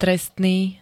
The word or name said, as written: trestný